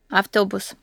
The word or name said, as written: autobus